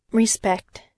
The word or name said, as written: respect